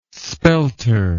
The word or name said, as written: spelter